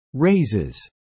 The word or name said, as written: raises